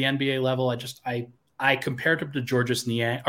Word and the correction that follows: Georges Niang: or George Niang, excuse me. I keep calling him Georges because that's how his name is spelled. But I was I was uh, told on a podcast that or on, uh, like on my own podcast that it is George Niang.